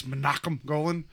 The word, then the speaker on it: Menachem Golan: I know how to say it. It's Menachem Golan.